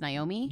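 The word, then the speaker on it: Niomi: It's N-I-O-M-I, Smart. – But it's still spelled Naomi. It's still pronounced Naomi.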